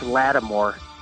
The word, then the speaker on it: Lattimore: Uh, I think it's Lattimore. i I, might I might be pronouncing his name wrong. No, you're saying right.